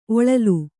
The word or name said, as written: ಒಳಲು